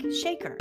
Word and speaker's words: Shekhar: One name note, Nina spells her name S-H-E-K-H-A-R and pronounces it like shaker. Like a pepper shaker. So please note that her last name is spelled with two H's.